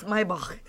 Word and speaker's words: Maybach: I have to say it right. Americans say Maybach. It's Maybach. – It's Maybach.